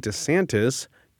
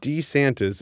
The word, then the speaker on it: DeSantis: Florida First Lady Casey DeSantis got her husband, Ron, to change the way he says his family name. – Wow! – DeSantis is how he would say it up until around his first gubernatorial election in 2018.